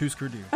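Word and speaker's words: Hüsker Dü: Always want to call this Husker Du. – I think it is pronounced Husker Du. – Okay, so it's Husker Du.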